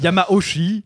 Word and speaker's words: Yamauchi: Corrige-le tout de suite. – Yamauchi. Merci.